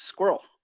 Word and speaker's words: SQRL: Yeah, it's um, it's Squirrel. – Squirrel. – Yeah, I love it. I joke I can't afford any uh, vowels, so it's spelled S Q R L.